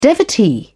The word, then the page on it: devotee: British English